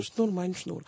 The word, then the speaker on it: Шнур, майн, шнур: Шнур. Я правильно произношу? – Да, «Шнур, майн, шнур».